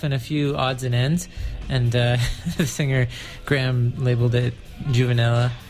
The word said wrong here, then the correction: Juvenalia: How do you say that? – Juvenalia. – Juvenalia. What did I say? – Juvenella.